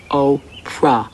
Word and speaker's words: Oprah: My name is Oprah. – Oprah. – No, no. – Oprah. – Oprah. – O. Oprah. – O. Pra. O. – Cha. Like opera, but with a long O.